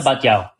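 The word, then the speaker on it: Batlló: And the other one is Casa Batlau. Batlau or Batyau? – You're butchering it, but go for it. I don't know how to see it. – Casa Batyau. – No. Oh, there you go.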